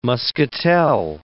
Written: muscatel

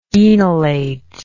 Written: enolate

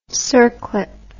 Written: circlip

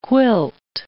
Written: quilt

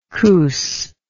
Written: coos

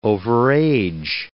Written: overage

